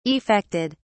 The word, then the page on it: effected: British English